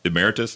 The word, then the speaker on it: Emeritus: I'm Emeritus, did I say that right? – Mm-hmm.